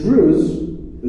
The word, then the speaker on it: Druze: The Druze, it rhymes with Jews, right?